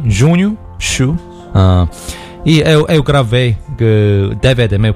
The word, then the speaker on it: Chu: Junho Xu, que é CHU. Procura aí no Instagram. – Xu de churrasco.